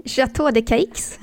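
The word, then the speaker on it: Château de Caix: Men sommaren spenderar hon ju också på sommarresidenset Gråstens slott eller Chateau de Sux. Så jag rätt nu? – Nej, Chateau de Caix.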